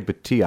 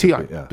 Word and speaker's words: Bhatia: Gets into a playoff with Bhatia, right? That's how it's pronounced? – Actually, Bhatia, I pronounce Bhatia. Bhatia, okay. Gets into a playoff, loses in the playoff.